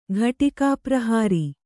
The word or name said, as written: ಘಟಿಕಾಪ್ರಹಾರಿ